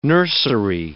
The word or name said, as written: nursery